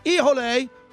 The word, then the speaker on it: Hijole: Ijole, spelled H-I-J-O-L-E, pronounced Ijole, where you can get it throughout town.